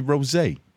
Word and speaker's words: Rosé: It's Ricky Rosé. – Yeah. It's the boss, man. – It rhymes with gay.